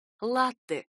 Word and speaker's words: латте: Ударение в этом слове ставится на первый слог. Оно пришло к нам из итальянского, в котором произносится именно так — «латте», и переводится как «молоко».